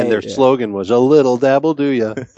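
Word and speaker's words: a little dab'll do ya: And so someone wrote in as that, and Adam, you pronounced that as? – Well, I thought it was the guy's name or whatever, and he's like, I was like, a leal dabble, and then you guys just lost it.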